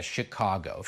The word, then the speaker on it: Chicago: Po angielsku się wymawia Chicago.